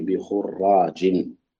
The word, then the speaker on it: خراج: بخراج، هنا بدون شده، بخراج على وزن غراب،